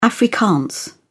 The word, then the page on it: afrikaans: British English